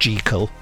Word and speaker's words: Jekyll: I feel like we ought to mention here that we do know that Jekyll is supposed to be pronounced Jekyll, but everyone has always said Jekyll, and it's just... it just upsets people to say Jekyll because they don't know what you're talking about.